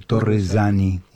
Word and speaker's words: Torresani: Y luego hablaremos con la licenciada María Elena Torresani, pronunciado en italiano, Torresani, una sola vez,